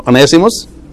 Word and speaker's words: Onesimus: If you see Philemon, in the book of Philemon, Paul meets with Onesimus. Am I pronouncing right the word? Onesimus? Yeah. He was a slave.